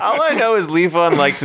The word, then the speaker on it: Levon: Is it Levon? – I mean, I just say it like the Elton John song. – Okay, which Elton John song is that? – Levon.